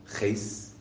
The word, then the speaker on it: Heth: So he shows up at this place, you know, this group of people called Heth, okay, in Hebrew pronounced Ches.